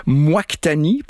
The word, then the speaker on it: Moictani: Moictani, parce que c'est comme ça que ça s'écrit, M-O-I-C-T-A. N-I, c'est le pseudonyme d'une artiste hispano-suisse qui s'appelle Tania Praz. Donc, c'est littéralement « Hey, moi, c'est Tani ».